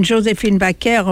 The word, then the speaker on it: Joséphine Baker: And Joséphine Baker, of course, that's the way we pronounce her name in France.